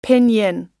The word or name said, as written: pinyin